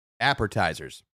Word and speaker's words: appetizer: So appetizers. – Yeah. – Appetizer. There's no R.